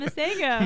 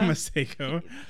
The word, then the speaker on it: Masego: We confirmed that's how you pronounce it. – Masego. – Masego. – He said so himself. – Yeah, he said so himself on the video.